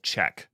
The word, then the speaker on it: Chkk: It's the company's called Check, but it's spelled C-H-K-K.